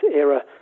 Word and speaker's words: Ira: I mean, it is spelled Ira, and some people in Belgium call her that, but it's Ira, because the film was originally called The End of an Era.